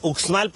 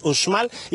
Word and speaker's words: Uxmal: Nos encontramos precisamente en el hotel Mundo Maya Nuevo Uxmal, Uxmal, podrían decir algunas personas extranjeras para tener mayor facilidad, pero realmente la pronunciación es Uxmal.